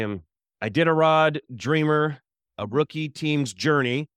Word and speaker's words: Iditarod: I did a tarot. Did I say it right? – Very close. I did a rod.